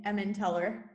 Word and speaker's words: Emmental: To break this down for the people at home listening, it is some sort of cheese called Emmental cheese. Do you know if I'm pronouncing that correctly? – Yeah, I think in English it's like, I would say Emmenteller, but. – Emmenteller, even better.